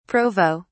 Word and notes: provo: British English

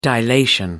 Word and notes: dilation: British English

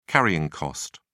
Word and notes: carrying cost: British English

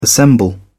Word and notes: assemble: British English

